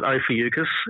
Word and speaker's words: Ophiuchus: Someone just announced that it was there, which I've always pronounced it Ophiuchus. Others are saying Ophiuchus. But yeah, Ophiuchus, which is... – Yeah, Ophiuchus is how I've always pronounced it. – Yeah. I've been a hard C.